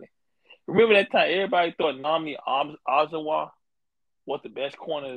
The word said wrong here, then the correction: Nnamdi Asomugha: We, we, let's pronounce his name again. Nnamdi Asomugha.